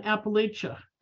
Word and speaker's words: Appalachia: So tonight, our presenter actually presented to culinary historians back in November on Appalachia. Did I say it right? – You got it.